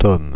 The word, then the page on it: TOM: Paris; [tɔm]